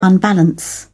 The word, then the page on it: unbalance: British English